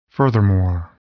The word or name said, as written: furthermore